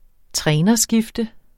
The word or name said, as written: trænerskifte